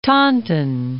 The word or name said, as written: Taunton